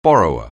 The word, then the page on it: borrower: anglais